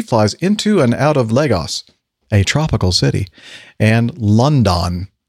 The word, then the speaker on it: Lagos: My question is for Captain Nick, because he flies into and out of Lagos. Did I say that right? – Lagos. – Lagos. Darn it.